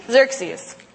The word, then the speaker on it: Xerxes: And the king of the Persian Empire is King Xerxes. Two X's. Had to ask Janie, am I pronouncing that right? Because that's a little tricky. King Xerxes.